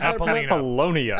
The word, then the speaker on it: Apollonia: At the same time, the kid meets Apollonia. Is that how we pronounce it? Apollonia. – Apollonia. – Apollonia. – Apollonia. Right. – Apollonia. There we go.